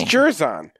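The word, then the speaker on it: Jer'Zhan: Yeah, because no one can pronounce Jerzon, so his nickname is Johnny. – It's Jerzon. We just did it.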